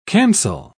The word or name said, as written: cancel